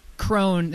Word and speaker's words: Krohn: So really quick, guys, so Larry Krohn, that's how you say your last name, correct? – Yes, sir.